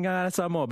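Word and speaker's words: Gagana Samoa: to penny my appy this morning and learn some choice words and expressions in Ngangara Samoa. But firstly, did I say that correctly? – Yeah, you did.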